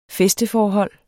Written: fæsteforhold

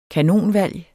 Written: kanonvalg

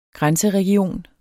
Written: grænseregion

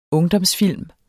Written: ungdomsfilm

